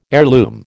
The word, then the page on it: heirloom: American English